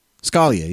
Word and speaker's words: Scalia: Scalia, yeah. – Scalia, yeah, that's how they say it, yeah.